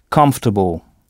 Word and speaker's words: comfortable: Pas de son on, puisque ce son n'existe pas en anglais. Et ici, l'ensemble COM se prononce comme. Et notez que OR est à peine audible, comfortable, et que c'est la première syllabe qu'il faut frapper. Comfortable. Bon, alors prenons un autre exemple. Si sœur se dit sister, S-I-S-T-E-R, comment dira-t-on votre sœur a fait un travail remarquable?